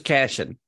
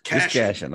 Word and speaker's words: Cashion: just Cashion. Cashion. That's a lot simpler than it looks.